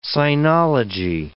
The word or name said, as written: sinology